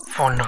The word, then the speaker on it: Phaune: phone radio. I mean, phone radio. It's spelled P-H-A-U-N-E. But it's pronounced phone.